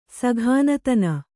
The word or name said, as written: ಸಘಾನತನ